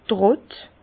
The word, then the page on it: d' Trott: Bas-Rhin; Herrlisheim